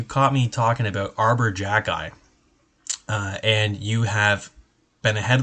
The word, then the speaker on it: Xhekaj: So his last name is spelt X-H-E-K-A-J. Wait, how do you get Jacki out of that? I don't even know. If it's phonetically, it's closer. If you spell it backwards, it looks like it would be closer to Jacki.